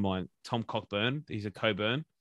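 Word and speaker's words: Cockbain: Why are the BBL commentators pronouncing Cockbane as Cobain? Surely the CNK aren't silent.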